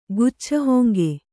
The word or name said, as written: ಗುಚ್ಛಹೊಂಗೆ